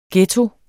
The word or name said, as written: ghetto